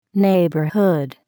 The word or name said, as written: neighborhood